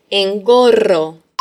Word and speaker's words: engorro: Se escribe con doble R, que es muy vibrante. Engorro.